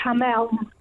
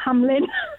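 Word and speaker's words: Hameln: Yeah, it's pronounced Hamel. Yeah, not Hamlin.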